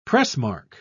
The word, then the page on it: pressmark: British English